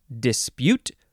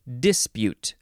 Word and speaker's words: dispute: Next is the second form of dispute or dispute. Emphasize either syllable.